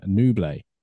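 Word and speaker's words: Nouble: Can we get the fan base to universally accept the pronunciation Nublé, as in Bublé?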